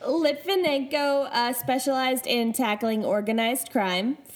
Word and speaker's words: Litvinenko: So Alexander Litvinenko, that's how I am choosing to pronounce it.